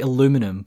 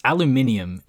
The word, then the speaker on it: aluminium: Also, the Americans say aluminum, but, you know, it's aluminium, and you'd think Walt, being the pedantic man that he is, would say it the right way.